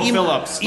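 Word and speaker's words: Emo Philips: It's no emo Phillips. – Emo Phillips. – Yes.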